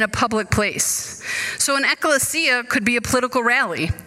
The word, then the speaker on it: ekklesia: But in the Greek, the word church, which is pronounced, I'll try it, ekklesia, didn't really mean anything spiritual or Christian at all.